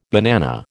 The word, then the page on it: banana: American English